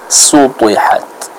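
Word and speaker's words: Sutihat (سُطِحَتْ): Sutihat. Yang mana dalam kata tersebut kita harus fokus untuk bisa apa? Menebalkan bacaan dari huruf ta, ya.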